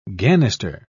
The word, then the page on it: ganister: British English